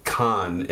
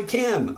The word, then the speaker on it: Cannes: Well, I kind of got disrupted because you corrected me when I pronounced it con, and you were like, "No, it's actually can. I was there. They swore I have to pronounce it this way." – And they told me, "Wow, it's like can is how they say it. It's not con. It's not Wrath of Khan like Star Trek, apparently. So can is where it's at."